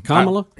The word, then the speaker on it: Kamala: So he is ready for Kamala. Isn't that how you say it? – That's it. – Okay. – Kamala? – Kamala.